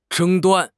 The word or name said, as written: 争端